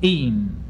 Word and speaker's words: even: Words ending in en, such as seven, s-e-v-e-n, heaven, h-e-a-v-e-n, and een, e-v-e-n, frequently cause difficulty for players. Each of these words is monosyllabic for Shakespeare, seven, heaven, and een.